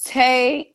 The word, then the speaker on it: Tay: It's Tay underscore Sheree, and that's how you find me because I guess somebody else got my have my name.